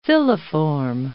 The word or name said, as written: filiform